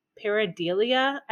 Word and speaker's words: pareidolia: It's like P-A-R-E-I-D-O-L-I-A. So pareidolia, I think, or pareidolia. – Are you sure it's not a P-H sound? – Pareidolia. No, there's no H. Pareidolia, I think, is how it's pronounced.